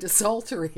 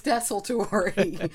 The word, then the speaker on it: desultory: And that's one that I always say desultory, actually, which is wrong. It's desultory. And I mean, we wrote it, and here I was like panicking about it, but it is desultory.